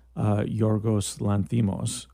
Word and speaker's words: Yorgos Lanthimos: The gentleman's name who made that movie and wrote it is Yorgos Lanthimos.